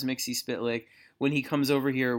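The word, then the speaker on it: Mxyzptlk: So James Tynan is adamant, like fight you adamant, that you're supposed to pronounce it Mixie Spitlick. And I had never heard that. – Really? That's interesting. – I always thought it was Mixaplick.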